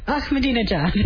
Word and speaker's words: Ahmadinejad: Ahmadinejad. – Well, the way to remember, Patty, is I'm in a jihad.